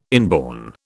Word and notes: inborn: British English

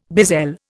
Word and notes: bezel: Indian

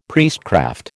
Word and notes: priestcraft: American English

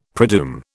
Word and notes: predoom: British English

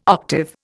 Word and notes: octave: Indian

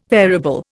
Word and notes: variable: Indian

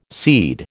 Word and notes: seed: American English

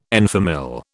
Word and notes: en famille: British English

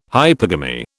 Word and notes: hypergamy: British English

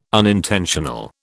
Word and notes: unintentional: British English

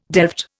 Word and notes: delft: Indian

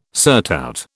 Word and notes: surtout: British English

